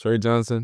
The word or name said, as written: Tre Johnson